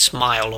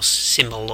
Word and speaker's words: SMIL: They're doing well on the ACID 3 test, although unexpected to pass all of it, because the ACID 3 includes stuff like I think it's supposed to be pronounced smile or symbol or SMIL,